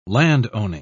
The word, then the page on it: landowning: British English